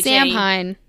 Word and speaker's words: Samhain: And I'm looking at the word Samhain, and it's spelled S-A-M-H-A-I-N. – Samhain. – Yeah, it's... – You're correct. – Yeah. – It is Gaelic, though. – Yeah. And so it's pronounced it makes no sense. Samhain. And yes.